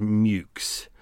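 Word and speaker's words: Meux: How long does a porter by Mayhew and Co. take to fully mature? For anyone who's a pedantic out there, I know that Mayhew is actually pronounced Mukes. I deliberately went through and went, oh, it's pronounced Mayhew and Co. because it's M-E-U-X.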